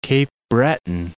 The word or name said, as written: Cape Breton Highlands National Park